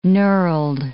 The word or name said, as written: knurled